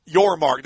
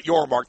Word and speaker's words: Yormark: Yormark. – There you go. Brett Yormark.